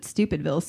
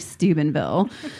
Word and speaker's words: Steubenville: I accidentally mispronounce it Stupidville sometimes when it's really Steubenville.